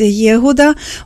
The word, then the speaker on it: Єгуда: Та от пригадую, ім'я Юда або єврейською це вимовляється Єгуда, означає так дуже цікаво хвалити Господа.